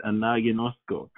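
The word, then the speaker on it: anagnosko (ἀναγινώσκω): That's how you say it, anagnosko.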